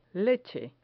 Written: leche

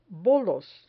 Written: bolos

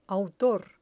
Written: autor

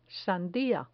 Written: sandia

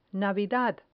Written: navidad

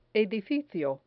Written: edificio

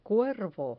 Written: cuervo

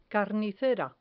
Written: carnicera

